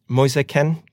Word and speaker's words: Moise Kean: Just, they're really good signing Moise Kean, correct pronunciation? – Yes, yeah. Absolutely.